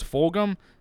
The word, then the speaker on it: Fulgham: Because Fulgham is how you say his name.